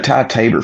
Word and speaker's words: Ty Tabor: And what was Ty Tabor? Is that how you say his name? – Yeah. Ty Tabor from King's X.